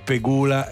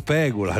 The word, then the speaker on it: Pegula: si dice Pegula e non Pegula, ad esempio, ci sono tantissime altre.